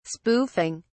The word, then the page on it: spoofing: British English